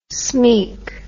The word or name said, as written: smeek